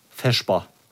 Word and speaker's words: Vesper: Im Schwäbischen wird das Wort Vesper als Vesper ausgesprochen.